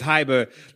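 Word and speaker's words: Hybr: And it's spelled like Silicon Valley style. That's H-Y-B-R. So don't go Googling for Hyber with an E-R. You know, don't go putting the E where there's no E. It's Hyber.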